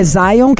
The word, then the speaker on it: Zion: É Zion, que é do Bob Marley, de Zion. Like a lion in Zion.